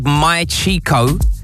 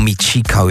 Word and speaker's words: Mi Chico: It's called My Chico, or Mi Chico if you want to say it properly.